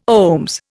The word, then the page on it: OHMS: Indian